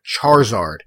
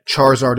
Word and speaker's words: Charizard: He said Charizard. It's actually pronounced Charizard. So, again, Charizard, the I is not silent.